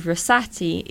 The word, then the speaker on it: Rossati: And Rossati is spelt RossAti.